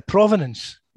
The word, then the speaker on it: provenance: And the other thing is that there's Hey, let's define, wait, before we go on, let's define that word providence. – Yeah, provenance. Yeah. – Provenance. Provenance. Let's promenade. – It's not from walking. No, it's not promenade. Provenance. I think it's pronounced provenance.